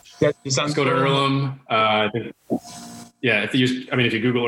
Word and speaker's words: Earlham: But yeah, it's Earlham College. Earlham spelled E-A-R-L-H-A-M, silent H.